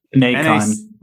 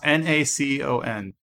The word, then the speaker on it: Nacon: Nacon. Nacon. – Pronounced Nacon. So like bacon. – Like imagine a horse convention. It's Nacon.